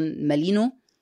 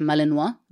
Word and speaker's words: مالينوا: طبعا مؤخرا في الموضه الجديده هي الكلاب المالينوا، اللي هي المصريين بيقولوا عليها احيانا مالينو، سواء النطق الصحيح مالينوا، وده كلب يعني شبه الجيرمن شيبرد جدا، بس هو بلجيكي في الاصل، وفي يعني فروق بسيطه في شكله عن الجيرمن شيبرد.